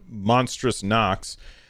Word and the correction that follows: Monstrum Nox: Monstrum Nox. I said that wrong.